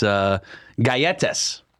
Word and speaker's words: galletas: It's galletas. Galletas? – Yeah, that's how you pronounce it.